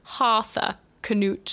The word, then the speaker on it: Harthacnut: He had a son called Harthur Canute. – No. – All one word. – Arthur or Harthur? – Harthur Canute.